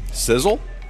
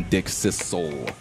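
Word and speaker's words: Dickcissel: Sizzle? – Sissel. Now, perhaps the pronunciation does lean a little bit more, but it is a double S, so I'm going Dick Sissel.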